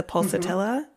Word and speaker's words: Pulsatilla: pulsatilla.